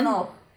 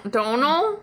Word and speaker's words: Domhnall: Domhnall it rhymes with tonal. He says it in multiple interviews. – Don- Donal? – Donal.